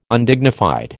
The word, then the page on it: Undignified: American English